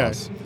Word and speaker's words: ROS: Is it ROS? What's the agreed pronunciation? – It is ROS. – It's ROS. – Okay. Ross.